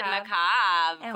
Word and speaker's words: macabre: Am I saying it right? Did I say it right? – You did. Macabre.